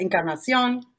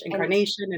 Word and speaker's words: Encarnacion: Yes, Monica Encarnacion in Spanish, Encarnacion in English.